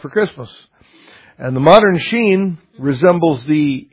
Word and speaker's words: Shin: It's the Hebrew letter Sheen or Sin. Some people will pronounce it Shin or Sin, but Sin, I don't, because it, as you'll see, it has two ways that it, that letter is used.